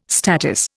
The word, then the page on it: statice: Indian